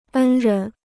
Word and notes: 恩人: ēn rén

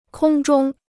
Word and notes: 空中: kōng zhōng

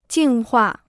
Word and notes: 净化: jìng huà

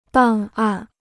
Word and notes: 档案: dàng àn

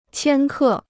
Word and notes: 千克: qiān kè

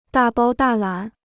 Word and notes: 大包大揽: dà bāo dà lǎn

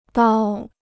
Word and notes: 刀: dāo

